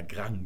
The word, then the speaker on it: Grand: Da sagt man natürlich beim Skat, ist die korrekte Aussprache ausschließlich der Grang mit G hinten. Das muss man also deutlich hören.